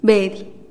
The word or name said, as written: bedih